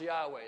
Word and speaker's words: Yahweh: But when the vowels are put in there, that's pronounced Yahweh.